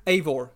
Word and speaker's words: Eivor: Ivor. – Oh, Eivor. It is Eivor. – Eivor. Okay. – It's Eivor.